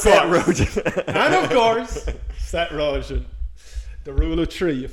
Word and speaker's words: Rogen: That was a callback to a different episode where Derek McGarrigle, the filmmaker, called Seth Rogen, Seth Rojan, like a fucking person who doesn't know how to spell Rojan. – I love how you're like, and you're a filmmaker, and you don't know how to pronounce Seth Rogen, like he's Laurence Olivier, like he's one of the great actors.